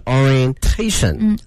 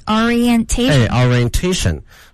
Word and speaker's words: orientation: 而且这个 i 和 e 中间是分开读的，所以呢，它的发音是 orientation。 – orientation。